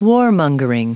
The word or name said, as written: warmongering